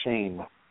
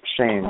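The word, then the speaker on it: Sheahan: Apparently it's Riley Shane. That's how he wants his last name to be pronounced, is Shane.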